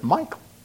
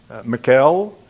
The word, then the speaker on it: Michal: How do you pronounce her name? Some pronounce it Michael. Oh, that's my name, and it's a male name. Michal is another way to pronounce it.